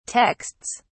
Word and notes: texts: British English

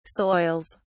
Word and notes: soils: British English

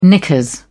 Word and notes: knickers: British English

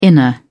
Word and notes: inner: British English

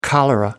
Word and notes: cholera: American English

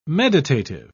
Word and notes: meditative: British English

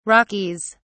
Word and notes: rockies: British English

